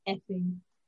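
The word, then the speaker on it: Ffinn: While the French aristocracy are losing their heads, quite literally, Two bored English noblemen, Sir Frederick Rodney Finn, pronounced Effing,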